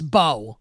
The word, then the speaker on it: Mbow: So they pull Hudson out and they put fifth round rookie Marcus Bow, whose last name is spelled M-B-O-W, pronounced Bow.